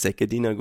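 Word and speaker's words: Szegediner: Und dann habe ich ihn gefragt, nach so 50 Jahren, du, jetzt will ich es mal wissen, wie wird mein Essen ausgesprochen? Ist es Segediner Gulasch? Er sagt, ja, ist Segedin.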